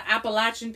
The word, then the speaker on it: Appalachian: Yes. Well, you could say Appalachian, but the Appalachian people say Appalachian. – Okay.